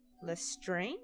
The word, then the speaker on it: Lestrange: You probably know that some Slytherin most renowned members include Severus Snape and Bellatrix Lestrange, is that how you pronounce it? – Mm-hmm.